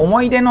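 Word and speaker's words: 思ひ出: 鈴木常吉ね、鈴木常吉さんで思い出で、この思い出の言いはですね、昔の読み方になってて、思ひでと書いて、ハヒフヘホの日ですね、思ひでで思い出です。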